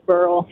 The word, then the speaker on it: Beryl: Hey, the guy that called and said it was pronounced Burl, I have relatives down around Sedalia, and it is Burl.